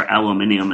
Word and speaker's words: aluminium: aluminum or aluminium, as the Brits say, and, and fish.